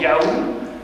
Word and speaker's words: YHWH: Alors 6, 6, qu'est-ce qu'il y a comme théologie Il y avait, donc, oui, il y avait YHWH, ça se prononce en fait Yahou,